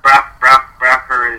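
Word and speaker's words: Braucher: Before we dive in too far, am I pronouncing that correctly? Is it Broucher or is it Broucher? Does it matter? – Broucher is good. That's fine.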